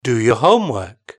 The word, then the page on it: do your homework: British English